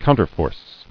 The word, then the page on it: Counterforce: coun·ter·force